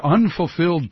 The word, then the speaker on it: Unfulfilled: The book is The Historic Unfilled I haven't said that right once. The Historic Unfulfilled Promise by Howard Zinn.